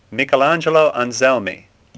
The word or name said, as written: Anselmi